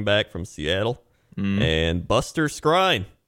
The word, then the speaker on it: Skrine: Bryce Callahan going to Denver, who was their good player there, and now they're replacing him with Buster Skrine. – Mm-hmm. – By the way, $21 million contract. – technically pronounced screen.